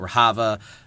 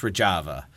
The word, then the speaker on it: Rojava: so I kept pronouncing it Rojava. It's Rojava.